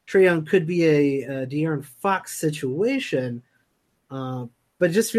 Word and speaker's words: De'Aaron: What about DeAndre Fox? – De'Aaron Fox? – Ooh. De'Aaron Fox. I always get that name wrong.